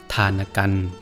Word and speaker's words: ทานกัณฑ์: ทานกัน